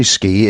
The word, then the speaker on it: ski: Ah, it's actually ski. It comes from Nordic languages, and it means actually cross-country racing and jumping on skis. Skiing, you have to be terribly careful, because particularly in France, it's an extremely rude word. But there are very few words written, SK, that are pronounced sh in English, and this, I think, was a misunderstanding.